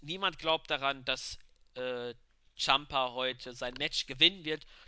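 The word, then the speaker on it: Ciampa: Und zwar Tommaso, jetzt Entschuldigung für die Aussprache falsch, denn er hat geschrieben, sein Name wird ausgesprochen wie Tampa oder Tampa, also Champa.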